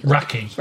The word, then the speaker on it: rakı: It's spelt R-A-K-I, so it looks like rakı. – Rakı. – Rakı. But it's not. It's rakı.